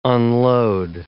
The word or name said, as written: unload